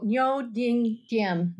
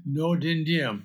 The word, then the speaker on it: Ngo Dinh Diem: Uh, the Lost Mandate of Heaven the American betrayal of how do you pronounce his name? Oh, Ngo Dinh Diem. – Ngo Dinh Diem, President of Vietnam, by Geoffrey Shaw.